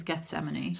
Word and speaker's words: Gethsemane: People pronounce it different ways, but the way they pronounce it in the musical is Gethsemane.